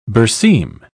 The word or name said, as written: berseem